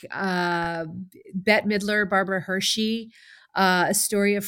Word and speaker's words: Bette Midler: young Bette Midler, I think.